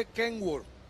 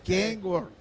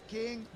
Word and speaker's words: Kenworth: Y se dice Kenworth. – Kenworth. Es King. No King. Es Kenworth. – Sí. Como se escribe.